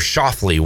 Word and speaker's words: Schauffele: Xander Schauffele, one shot off the lead in the first round of the U.S.